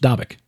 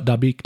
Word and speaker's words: Dabiq: It's actually, Dr. Furnish says it's actually pronounced Dabiq. – Oh, well, I bow to him. – I did not know. I would have thought Dabiq too, but no, it's Dabiq.